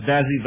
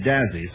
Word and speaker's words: Dazzy: Well, apparently, according to Mr. Woodward, Daisy was Dazzy Vance's breakaway pitch. He called it Daisy, and when he was in the South, they called it Dazzy, sort of slurred over the...